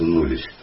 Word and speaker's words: knowledge: Это knowledge, знание. Ну, вы знаете, в английском языке кей перед согласными даже не читается. Да? Это knowledge. Вот.